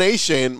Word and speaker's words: Achane: But, man, later in the season, and particularly that bowl game against North Carolina, is it Devon Achane, however you say his name? – Devon Achane.